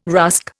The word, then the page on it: rusk: Indian